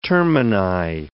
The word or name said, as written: termini